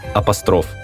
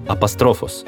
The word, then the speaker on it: апостроф: Оно пришло в русский язык из греческого «апострофос», обращенный в сторону. Ударение оригинала сохранилось и при заимствовании. «Апостроф», а неверное «апостроф» появилось как раз из-за стремления к ритмическому равновесию.